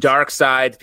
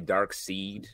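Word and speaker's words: Darkseid: Dark Side, people say Dark Seed. – Yeah. – Or you've been one of you. – I always did that. – You said Dark Seed.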